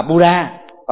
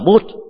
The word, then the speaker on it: Buddha: Đọc Anh hóa thì đọc là Buddha, còn đọc chuẩn là Bud.